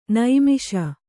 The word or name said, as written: ನೈಮಿಷ